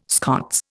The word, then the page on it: Scots: Indian